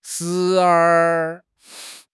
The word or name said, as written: 时而